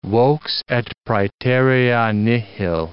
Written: vox et praeterea nihil

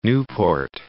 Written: Nieuport

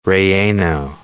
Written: chile relleno

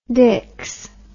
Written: diks

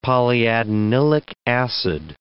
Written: polyadenylic acid

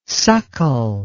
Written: suckle

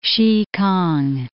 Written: Sikang

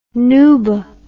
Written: nuba

